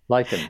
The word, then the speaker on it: lichen: I've had some, again, because I put a call out for questions, I had people ask me about lichen sclerosis and also lichen. – Lichen. – How do I say that? Lichen.